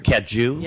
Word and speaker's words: Cajou: Am I pronouncing that right? – You are. – Cajou? – Yeah. Cajou is good.